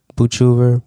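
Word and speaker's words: Boochever: Outwin Boochover. Boochover. Boochover. I'm saying it right.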